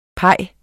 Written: pie

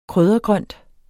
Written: kryddergrønt

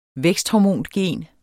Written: væksthormongen

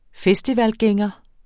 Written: festivalgænger

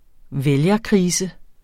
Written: vælgerkrise

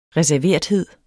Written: reserverethed

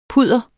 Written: PUDDERETS

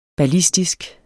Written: ballistisk

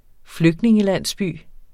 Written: flygtningelandsby

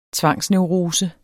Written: tvangsneurose

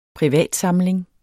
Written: privatsamling

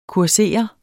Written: kursere

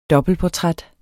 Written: dobbeltportræt

